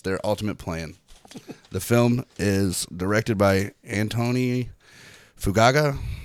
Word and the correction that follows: Antoine Fuqua: Antoine Fuqua.